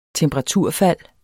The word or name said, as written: temperaturfald